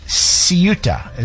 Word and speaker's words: Ceuta: Ceuta is how it's pronounced.